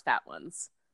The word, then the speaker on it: Fatone: Yeah, it's spelled exactly like Fatone, but it's pronounced Fat Ones.